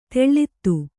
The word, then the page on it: ತಳೆತ: taḷeta